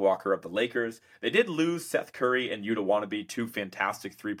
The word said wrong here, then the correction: Watanabe: point shooters, or Watanabe, I should say, Yuta Wannabe.